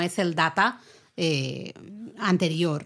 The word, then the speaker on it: Data: Porque en español, claro, dices data, que es como un eh, estadounidense pronunciaría también, más o menos, ¿no? Eh, la palabra data, pero al, personaje lo llaman siempre en la serie, cuando la escuchas en versión original, al estilo británico, dicen Data.